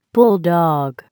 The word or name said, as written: bulldog